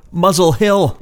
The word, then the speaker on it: Muswell Hill: Kinks, Uncle's Son, off of the album of 1971, Muswell Hillbillies, which is a play on the London neighborhood of Muswell Hill, Muzzle Hill, as it's pronounced locally.